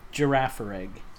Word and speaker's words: Girafarig: Girafarig.